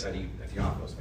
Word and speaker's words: Theophilus: Theophilus, I said it right.